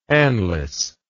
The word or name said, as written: anlace